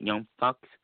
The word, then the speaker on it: Young Bucks: Kenny Omega versus Adam Hangman Page, the Tag Team Champions, versus the Young Bucks. Or how you like to say it, Young Bucks. Did I say it right? Yeah. – Yeah, you said it right the second time.